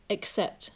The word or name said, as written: except